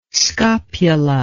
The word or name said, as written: scopula